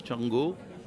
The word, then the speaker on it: Xango: Django, qui s'écrit X-A-N-G-O pour ceux qui ne sauraient pas écrire le catalan, c'est ça ? – C'est ça, mais Django est un demi-dieu de la mythologie Yoruba. Donc en fait, ça nous transporte très loin en Afrique. Et effectivement, ça se prononce comme en catalan.